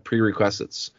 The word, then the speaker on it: prerequisites: prerequisites. I, I struggle with that word. Sorry, I, I mispronounce that word all the time. Pre request request. I can't say it, as hard as I try. Prerequisites.